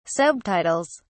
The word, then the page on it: subtitles: British English